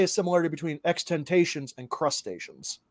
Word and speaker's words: XXXTentacion: Now, XXXTentacion, I believe is how it's pronounced. XXXTentacion.